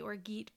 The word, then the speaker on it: orgeat: And then about 1 ounce of Orgeat, which is spelled O-R-G-E-A-T. And I always want to say Orgeat, but I don't think that's right. I think it's Orgeat, which is like an almond syrup.